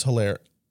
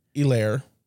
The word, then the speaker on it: Hilaire: Clyde Edwards Helaire you don't pronounce the H. Clyde Edwards Hilaire. – Or Hilaire.